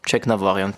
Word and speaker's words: Tjeknavorian: Äh, aber original im, in der armenischen Sprache ist es erstens das E gibt's nicht, Check gibt's nicht, das ist Jugnavorjan. Da ist es tatsächlich äh, am Ende die Betonung, aber Check Navarian passt.